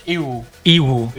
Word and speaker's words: Yiwu: And the other one, the Y-I-W-U, is what? – We pronounce it in English. – It's what? – Iwu.